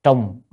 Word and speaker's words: trùng: Trùng trùng, chữ này có hai âm, một là trọng hay là trùng, nhưng ở đây là bạn phải đọc là trùng, nếu trọng là nặng.